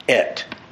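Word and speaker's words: et (את): It's a Hebrew word called, that's pronounced et. Et.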